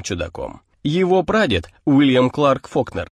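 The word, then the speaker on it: Falkner: Тогда его фамилия еще писалась без буквы «Ю». Фолкнер и произносилась как Фокнер, а вырос в расположенном неподалеку городке Оксфорд на севере штата, куда он перебрался вместе с родителями.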